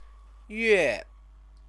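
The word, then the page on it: 月: yuè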